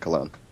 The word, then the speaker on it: Colon: It's pronounced cologne, like the cologne. – Right. Yes. Spelled like colon, smells like cologne.